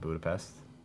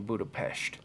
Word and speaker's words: Budapest: You mean Budapest? – Budapest. Budapest, Hungary. – Budapest. – I didn't know that was really. – That's how you're supposed to pronounce it.